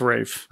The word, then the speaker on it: Ralph: we've got Ralph Fiennes back as M, or Rafe. Is it Rafe? – It's Rafe.